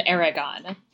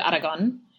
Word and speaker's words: Aragon: Yep, it's in this scene that they start to refer to him as Tristan of Aragon. Aragon, or if I'm going to be fancy about it, Aragon, is a...